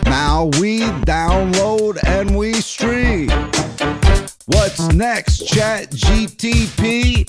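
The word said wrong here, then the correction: ChatGPT: Did I say it right? – No. – So you, close. – It's GPT.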